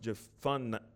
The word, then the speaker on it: Jephunneh: It actually is pronounced Jephunneh. Or that's how Alexander Scorby says it, I guess.